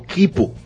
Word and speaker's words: quipu: Esto se supone que corresponde a lo que es una comunicación antigua conocida como Kipu, se escribe Q-U-I-P-U,